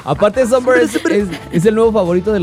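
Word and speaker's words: Sombr: Pero a mí siempre me sacó de onda su nombre, pero es Somber. – Somber. – Somber. – Se escribe S-M-B-R, ¿no? – No, S-O-M-B-R. Y es Somber. Como... – Somber. Somber. Es como sombra sin la A.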